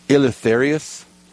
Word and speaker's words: Eleutherius: Pope Saint, and let's see if I can say this right, Eleutherius, if I'm saying that right.